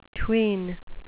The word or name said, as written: tween